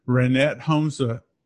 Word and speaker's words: Ranette Holmseth: Hey everyone, Follower of One, and today I want to introduce to you Ranette Holmseth.